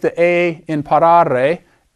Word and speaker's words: parare: First conjugation has A-R-E in the infinitive. Parare. That's a long A.